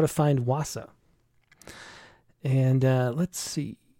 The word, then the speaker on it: Wausau: How do you say that? Wausau? – Wausau.